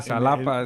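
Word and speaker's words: Xalapa: No, yo de hecho, yo he dicho mal. Satapa porque en. – Ah, Salapa.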